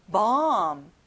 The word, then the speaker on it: bomb: 폭탄, bomb. 어 소리가 나고요. 끝에 ㅂ 발음은 안 나요. bomb.